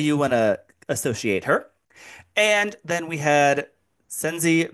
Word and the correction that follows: Shenzi: Ben, I can't ever pronounce their names, Chris. – Shenzi, Banzai, and Ed. – Yes, thank you.